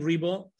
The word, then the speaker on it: Ribo: So everybody looking for me, Angel Ribo, last name Ribo, R-I-B as in boy, O.